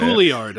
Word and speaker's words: Juilliard: Is that a weird way to pronounce Juilliard? I guess I've never really said it out loud because I'm not a class. – Yeah, that's how you say it.